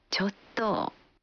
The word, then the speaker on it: ちょっと: 좋도 라고 어미를 길고 약하게 발음하면 말하기 거북한 것을 말한다는 느낌이 돼 거절하는 표현으로 사용됩니다.